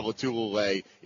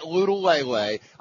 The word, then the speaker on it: Lotulelei: Well, we might, but we'd be pushing our luck here to try to get Star Lotulelei in. By the way, it is Lotulelei, Tony, not Lutulele.